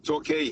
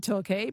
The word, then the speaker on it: Torquay: Torquay. – Torquay. – Well, Torquay. Yeah. – It's spelt talk on my screen, like motor talk. But I thought, oh, it might be Torquay, but okay.